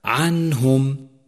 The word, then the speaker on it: عنهم: فيلفظ هكذا عنهم، تلفظ النون كاملة من غير زيادة شيء، وحروف الإظهار هي الهمزة والهاء والعين والحاء والغين والخاء.